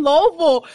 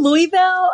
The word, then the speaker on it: Louisville: like Louisville, Louisville, and I'm like, who is that? I'm from Louisville. we don't pronounce any syllable in it.